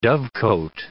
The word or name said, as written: dovecote